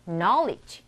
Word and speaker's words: knowledge: K-N-O-W-L-E-D-G-E. knowledge 아니고 knowledge로 읽어요.